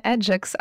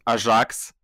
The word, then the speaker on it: Ajax: Аджекс, Аджекс і навіть Ажакс. Тому що я слухав якісь французькі подкасти, там ведучий француз, він говорив англійською, але він казав Ajax. Тому що французький так читається ця назва.